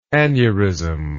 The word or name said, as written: aneurismally